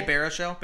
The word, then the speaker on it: Baruchel: Jay Baruchel.